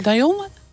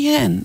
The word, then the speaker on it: Dionne: Ja, dan heb Dionne. Ik het toch goed. – Diane, Dionne. Een moeilijke naam. – Ik zeg al mijn hele leven Dionne. Dan heb ik het helemaal fout uitgesproken.